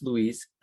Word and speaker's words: Luis: First and foremost, if you want to connect with me, last name is spelled C-U-N-E-O. First name is Luis, L-U-I-S, and connect with me on LinkedIn.